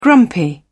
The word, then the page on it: grumpy: British English